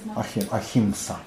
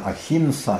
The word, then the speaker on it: ахимса: Ахимса. Ахимса, да. Иной раз произносят «н», «ахимса», «ахимса», бог его знает. Но в основном произносят «м» в середине, «ахимса».